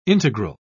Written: integral

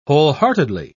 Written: wholeheartedly

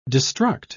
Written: destruct

